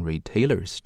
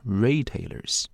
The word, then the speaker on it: retailers: 那注意 retailers 这个单词在读的时候呢，要把重音给它放对，它是在第一个音节上重读。好吗？不能读成 retailers, 这不对哈。 retailers 没提这三个线上零售商的名字，说明呢最重要的事儿呢不在这儿，而是在后边，对吧？我们看这个动宾搭配 have dropped a fast fashion brand Boohoo, 说他们呢下架了快时尚品牌 Boohoo。